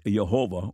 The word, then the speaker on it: Jehovah: but represent the original and true pronunciation of the profoundly significant divine name. The commonly repeated modern idea that the pronunciation Jehovah is a late and incorrect invention, while Yahweh is the true pronunciation of the name, is false.